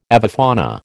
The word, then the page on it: avifauna: American English